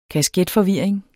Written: kasketforvirring

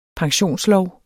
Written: pensionslov

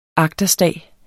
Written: agterstag

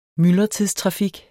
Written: myldretidstrafik

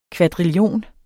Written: kvadrillion

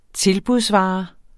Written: tilbudsvare

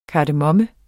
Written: KARDEMOMME

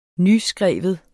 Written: nyskrive

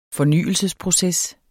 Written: fornyelsesproces

